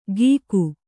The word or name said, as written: ಗೀಕು